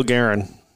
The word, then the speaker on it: Guerin: Any of the listeners who haven't seen, Zach Parise received a call from, how do you say your DM's name? Garen? – Yeah, Bill Guerin.